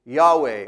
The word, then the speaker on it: Yahweh: The Hebrew word here translated into English as Lord is the special personal name of God that's represented by the Tetragrammaton, transliterated into English with those four letters, YHWH, and then we approximate the pronunciation of it with Yahweh or Yehovah.